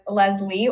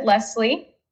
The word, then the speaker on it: Leslie: And you can pronounce it as Leslie or Leslie. Funny enough, my family pronounces it Leslie. I guess that's the way that you'd pronounce it in Spanish. And then in English, you'd pronounce it Leslie.